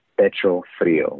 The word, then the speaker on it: pecho frío: And in Spanish, it's it's pronounced pecho frío, which translates to cold chested.